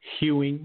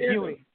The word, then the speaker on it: Ewing: I remember when Doc Bay used to call Patrick Ewing like, Hewing with an H. – Yeah, Hewing. Patrick Ewing.